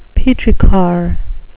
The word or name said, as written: petrichor